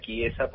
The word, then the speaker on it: Chiesa: Mirá, me dicen Chiesa, pero en realidad se pronuncia Chiesa porque es un apellido italiano.